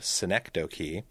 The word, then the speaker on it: Synecdoche: I know that Synecdoche, pronounced Synecdoche,